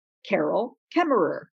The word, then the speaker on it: Carol Kaemmerer: So if you were to use the speaker icon beside my name, you would hear me say, Carol Kaemmerer.